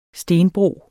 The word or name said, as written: stenbro